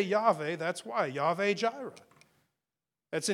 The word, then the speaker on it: Yahweh: I say Yahweh, like Y-H-W-H. Now, the W is pronounced like a V.